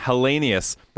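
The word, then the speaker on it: Helenius: Can't really pronounce Hellenius. – This guy's Hellenius.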